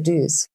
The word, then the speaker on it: Dyes: Und sie stammt aber tatsächlich aus ähm, einem Hörspiel von Ihnen und Anke Dyes, wenn ich sie so hm, richtig ausspreche. wie, wie spricht man sie aus? Anke Düs. Hm. Düs, ah, danke.